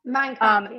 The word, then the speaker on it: Mein Kampf: Physical fitness has always been central to the far right in Mein Kampf. Hitler, I may have said that wrong. – Mein Kampf, yeah.